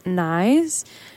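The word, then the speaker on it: Niis: Right before that was Low Life by, I think it's pronounced Nice. It's N-I-I-S.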